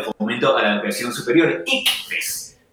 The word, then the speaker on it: ICFES: Recientemente los estudiantes de bachillerato de toda Colombia acaban de presentar las pruebas SABER, anteriormente conocidas como los exámenes del IFEX.